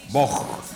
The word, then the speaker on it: Vogue: Qué manera de arrancar el programa con Madonna, esta canción que se llama Vogue, ¿cómo se dice? Vogue, moda, Vogue.